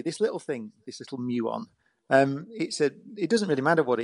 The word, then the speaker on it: muon: I don't know how you pronounce the muon, M-U-O-N. – It's a muon, yeah.